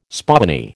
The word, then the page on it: spoony: American English